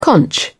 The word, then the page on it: conch: British English